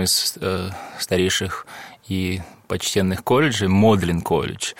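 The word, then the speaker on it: Magdalen: Хотя оно пишется Магдален, то есть, вот, но произносится именно в отношении колледжа Модлин.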